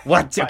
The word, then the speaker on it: Wacci: Wacci? It's embarrassing if you pronounce it wrong. Wacci, okay, wacci, good.